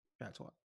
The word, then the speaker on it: patois: Is it patio? Is how you say it? – Patois.